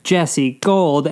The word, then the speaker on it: Jesse Gold: My name is Jesse Gold, and that's spelled J-E-S-S-E-G-O-L-D.